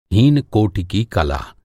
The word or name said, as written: हीन